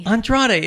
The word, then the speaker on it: Andrade: Andrade. – It's exactly as it's spelled.